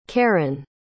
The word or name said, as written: karan